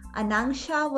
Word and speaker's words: Anangsha: Okay, so actually in Hindu mythology, Anangsha was the foster sister of Lord Krishna.